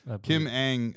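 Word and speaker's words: Kim Ng: I believe Kim Ang, YouTube gave me the pronunciation.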